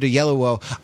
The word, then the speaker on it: Oyelowo: And uh, for David Oyelowo, I found a good trick for pronouncing his name. It's the word yellow surrounded by the syllable O.